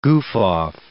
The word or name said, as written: goof-off